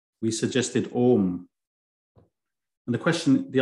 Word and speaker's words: Om: That's why you see sometimes car number plates with A-U-M written on it. So when you pronounce it, it's Om, A-U-M, and when you write it, it's O-M.